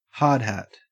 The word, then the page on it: hardhat: Australian English